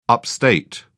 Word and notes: upstate: British English

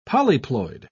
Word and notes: polyploid: British English

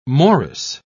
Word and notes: morris: British English

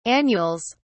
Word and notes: annuals: British English

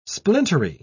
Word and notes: splintery: British English

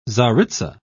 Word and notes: czaritza: English; British English

